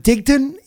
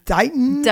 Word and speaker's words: Dighton: Is it Digton? Is it Dighton? – Dighton. – Dighton. – Dighton. – It's Dighton. – Dighton. No one says the T.